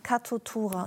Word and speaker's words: Katutura: Katutura.